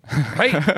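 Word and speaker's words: Hey (はい): How do you say yes again? – Hey. – No, it's a... – Yes, yeah, hi.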